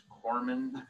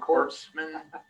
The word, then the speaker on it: corpsman: Navy, became a hospital corpsman. That's pronounced corpsman, not corpsman. Corpsman.